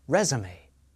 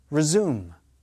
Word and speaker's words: resume: Stress is on the first syllable of the noun, resume. Second syllable of the verb, resume.